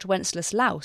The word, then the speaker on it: Wenceslaus: It's based on one of the many popular medieval myths about Wenceslaus I, which is the English pronunciation.